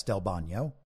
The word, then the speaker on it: Del Bagno: Del Bagno, and I think that this is the Italian pronunciation of his name. It's spelled Del Bagno, but I'm pretty sure that's Del Bagno.